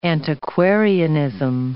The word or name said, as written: antiquarianism